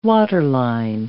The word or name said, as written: waterline